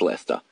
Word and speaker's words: Leicester: Apparently, North Americans cannot pronounce Leicester. – That does not look like the word Leicester. – It's Leicester.